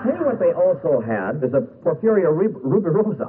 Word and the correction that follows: Porfirio Rubirosa: I can never pronounce. I knew I'd have trouble. You know, this is the problem. You buy cheap popcorn, what are you going to do? Porfirio Rubirosa. Yeah, there's one of these Rubirosa dolls.